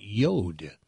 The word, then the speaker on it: Jord: But the watches, they're J-O-R-D, but they pronounce it Yod.